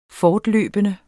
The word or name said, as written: fortløbende